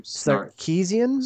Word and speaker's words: Sarkeesian: Sarkeesian.